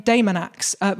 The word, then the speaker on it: Daemonax: And that is a man named Daemonax, which I'm pronouncing like that to try and avoid the fact that it is spelled Demon Axe, like an Amityville horror.